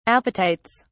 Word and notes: apatites: British English

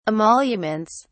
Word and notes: emoluments: British English

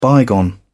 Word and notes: bygone: British English

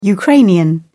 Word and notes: Ukrainian: British English

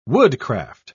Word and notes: woodcraft: British English

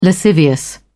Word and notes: lascivious: British English

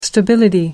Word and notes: stability: American English

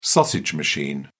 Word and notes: sausage machine: British English

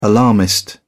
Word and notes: alarmist: British English

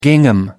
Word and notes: gingham: American English

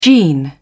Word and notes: gene: British English

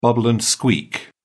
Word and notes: bubble and squeak: British English

